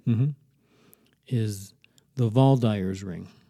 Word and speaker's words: Waldeyer's: Spelled with a W-A-L-D-E-Y-E-R apostrophe S. – Spell it again. – W-A-L-D-E-Y-E-R-S. – Hmm. Ring. – Yeah, and I guess in German you'd say the V instead of the W, Valdyer.